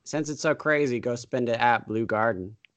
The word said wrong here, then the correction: Blue Garten: Actually, Blue Garten. – That's how good. – Spelled. Blue Garten. June 20th. – Spelled like blue cheese and Garten with a T.